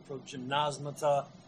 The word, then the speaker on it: progymnasmata: By the way, the Greek word is pronounced a couple of different ways by people. I have some friends in my church who teach classical languages, and they use the soft G, the progymnasmata. Some people use the hard G.